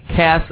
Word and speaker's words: Cass: Possum Cass, pronounced like gas with a K in the front.